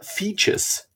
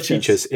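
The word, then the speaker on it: Fejes: Features, heißt der.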